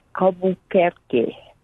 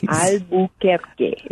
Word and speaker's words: Albuquerque: Playing from Albuquerque. Did I pronounce that correctly, or do you all pronounce it differently there? Albuquerque, New Mexico? – Well, it's Albuquerque. – Oh, nice. – Albuquerque is correct.